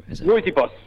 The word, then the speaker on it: Rooibos: Rooibos. – Rooibos. – Rooibos.